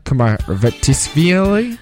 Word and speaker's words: Kumaritashvili: Nodar Kumaritashvili.